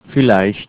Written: vielleicht

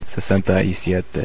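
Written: sesenta y siete

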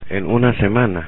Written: en una semana

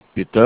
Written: Bitte

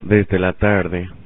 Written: desde la tarde

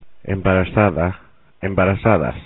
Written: embarazada

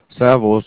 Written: Servus

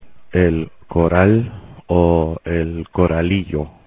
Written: el coral, el coralillo

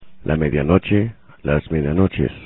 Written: medianoche

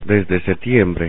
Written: desde setiembre

